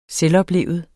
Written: selvoplevet

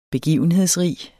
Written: begivenhedsrig

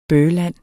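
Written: BØHLAND

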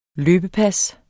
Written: løbepas

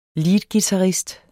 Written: leadguitarist

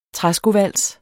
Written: træskovals

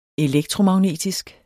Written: elektromagnetisk

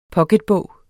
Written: POCKETBOG

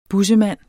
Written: bussemand

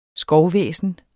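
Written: skovvæsenet

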